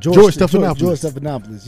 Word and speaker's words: Stephanopoulos: Stephanopoulos. Yeah, that's his last name, right? I'm saying it right? – George Stephanopoulos. Yeah, there we go.